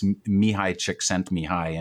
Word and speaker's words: Mihaly Csikszentmihalyi: It's even harder to pronounce, but it's Mihaly Csikszentmihalyi.